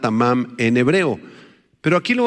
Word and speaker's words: tamam: La palabra hebrea tamam, con acento en la segunda A, T-A-M-A-M,